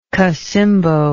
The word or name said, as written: cacimbo